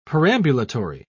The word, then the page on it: perambulatory: British English